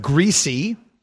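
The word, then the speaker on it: Griese: His last name is pronounced Greasy.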